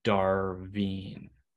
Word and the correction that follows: Darvo: No. CHL Goalie of the Year, Nathan. How do you say his name? – Darvo. – I want to see if Google would tell me. Darvo. Nathan Darvo.